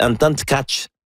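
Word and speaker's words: Anton Tkáč: Il dit prononcez bien vite en tchèque Anton Tkach.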